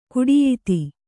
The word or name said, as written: ಕುಡಿಯಿತಿ